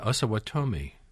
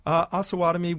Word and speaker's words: Osawatomie: And so John Brown is leading one of these groups, and they lose a fight at Osawatomie. – Mm-hmm. – What was that all about? – Osawatomie was one of these. – Osawatomie.